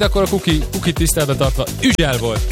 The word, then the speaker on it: Hugel: Francia nyelvtelten Hugel, amúgy pedig nagyon durva, de Hugel a helyes kiejtés.